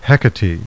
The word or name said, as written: Hectate